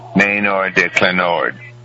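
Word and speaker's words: Manoir d'Alanord: Maynard de Clenord.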